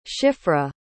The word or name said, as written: Shifrah